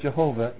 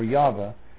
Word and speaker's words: Yahweh: By the way, notice how many times the word Lord is caps, indicating this is the word Jehovah in the Hebrew, or Yahweh, if you pronounce it correctly.